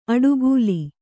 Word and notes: ಅಡುಗೂಲಿ: aḍugūli

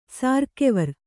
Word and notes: ಸಾರ್ಕೆವರ್: Kannada

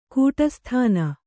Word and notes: ಗಡಸುತನ: gaḍasutana

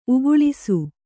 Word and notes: ಉಕ್ಕಳಿಸು: ukkaḷisu

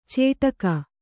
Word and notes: ಚೇಟಕ: cēṭaka

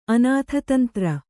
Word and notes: ಅನಾಥತಂತ್ರ: Kannada

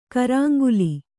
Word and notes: ಕೊರಂಗಲ: koraŋgala

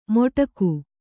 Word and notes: ಮೊಟಕು: Kannada

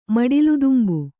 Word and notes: ಮಡಿಲುದುಂಬು: maḍiludumbu

